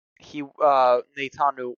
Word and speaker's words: Netanyahu: And with this current conservative government, it's not looking like Netanyahu wants to. That's how you say his name, right? Close enough. – Yeah, I think so, yeah.